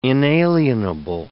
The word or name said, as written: inalienable